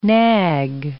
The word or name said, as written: nag